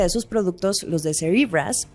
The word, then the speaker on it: Cerebras: Se escribe Cerebras como cerebro, pero se pronuncia Cerebras.